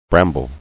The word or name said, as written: Brambly